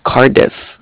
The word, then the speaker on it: Cardiff: It's Bank of Cardiff, and it's spelled C-A-R-D-I-F-F, like different.